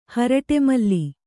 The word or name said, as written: ಹರಟೆಮಲ್ಲಿ